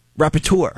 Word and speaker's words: Rapporteur: R-A-P-P-O-R-T. – Hold on, hold on. For National Dyslexic Week, go ahead. – R-A-P-P-O-R-T-E-U-R. – Rapporteur. You're close. – All right, good. – Yeah. No, say it again. – Rapporteur.